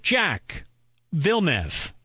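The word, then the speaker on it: Jacques Villeneuve: But this driver, despite having the exact same first and last name, chooses to pronounce it Jack Villeneuve.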